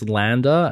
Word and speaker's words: Landr: So I use Landr, and it's pronounced. It's actually spelled L-A-N-D-R, so without the E, because it's trying to be cool.